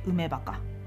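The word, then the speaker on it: 埋め墓: で 1 つ目のお墓これは梅墓という墓になります埋めるに墓と書いて梅墓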